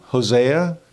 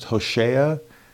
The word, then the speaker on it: Hosea: The, his name, Hosea, could also be pronounced Hoshea, which is the same root as Yeshua, which means salvation.